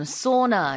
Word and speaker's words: sauna: 우리가 이제 사우나라고 하는 게 영어로는 sauna 이렇게 발음을 하거든요.